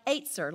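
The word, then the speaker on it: Etzer (עֵזֶר): And that word is E-Z-E-R in the Hebrew. And when I looked it up on how to pronounce on the internet, it says it's like Etzer, like the number eight, Z-E-R, Etzer.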